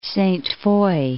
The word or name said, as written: Sainte-Foy